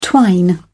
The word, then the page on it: twine: British English